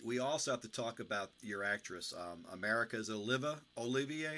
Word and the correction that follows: America Olivo: or how does she pronounce that? Do you know? – America Olivo. – America Olivo.